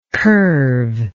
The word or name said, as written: curveless